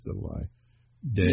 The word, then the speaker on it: Diddle I Day: And I mispronounced. I have it right in front of me, but I said Diddle I Dan, which that could be another song someday, but this one's Diddle I Day.